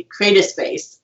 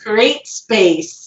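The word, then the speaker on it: CreateSpace: And I always say Create a Space. And they're like, no, it's Create Space.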